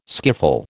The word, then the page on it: skiffle: American English